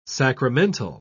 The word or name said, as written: sacramental